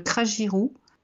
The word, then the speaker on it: crajiru: Ceci étant, généralement sur des boutons particuliers, moi j'ai plutôt tendance à utiliser des choses comme le cragirou, que je trouve très efficace. – Comment ça s'écrit, cragirou Ça s'écrit C-R-A-J-I-R-U.